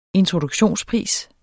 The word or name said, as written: introduktionspris